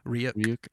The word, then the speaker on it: Ryuk: Ryuk.